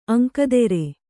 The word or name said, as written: ಅಂಕದೆರೆ